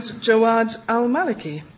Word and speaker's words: Jawad al-Maliki: And Jawad al-Maliki.